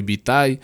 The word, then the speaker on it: Vitai: me pueden buscar como Yo Soy Vitay, Vitay V-I-T-A-I, eso quiere decir que Vitay es la primera parte, o la Sí, Vita es la primera parte de mi apellido, UE.